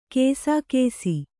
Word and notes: ಕಸಕಸೆ: Kannada

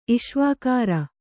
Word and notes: ಇಷ್ವಾಕಾರ: Kannada

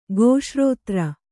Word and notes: ಗೋಶ್ರೋತ್ರ: gōśrōtra

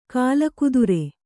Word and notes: ಕೀಲುಕುದುರೆ: kīlu kudure

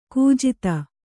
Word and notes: ಕುಜಾತೆ: kujāte

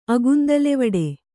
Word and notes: ಅಗುಂದಲೆವಡೆ: kannada

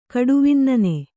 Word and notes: ಕಡುವಿನ್ನನೆ: kaḍuvinnane

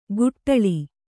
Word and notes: ಗುಟ್ಟಳಿ: guṭṭaḷi

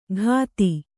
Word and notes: ಘಾತಿ: ghāti

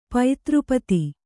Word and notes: ಪತ್ರಿಪದ: Kannada

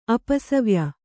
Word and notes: ಅಪಸವ್ಯ: apasavya

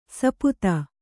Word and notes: ಸುಪಥ: kannada

